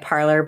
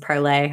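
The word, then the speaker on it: Parler: It's probably from one of these gross little, I know that they're pronouncing it parlor, but I think it's supposed to be pronounced parlay.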